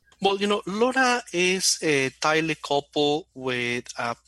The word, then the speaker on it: LoRa: And now, so the big thing behind Semtech, right, is the LoRa technology, right, which is L-O-R-A. But I was told it's pronounced just like the lady's name, LoRa, right? – Absolutely, yeah.